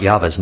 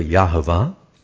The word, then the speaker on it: Yahweh: Wherever it says L-O-R-D in English, the underlying Hebrew is spelled Y-H-V-H, known as the Tetragrammaton, or four letters, Y-H-V-H, and it's pronounced Yahweh or Yahovah.